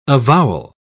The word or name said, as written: avowal